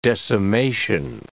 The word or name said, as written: decimation